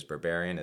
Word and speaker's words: Berberian: That's N-A-T-E dot. And my last name is Berberian. Is he pronounced correctly? That's B-E-R-B-E-R-I-A-N.